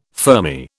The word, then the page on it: fermi: British English